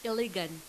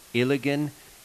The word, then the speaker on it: Iligan: How do you say the name of your city? – Iligan. – Iligan in the Philippines.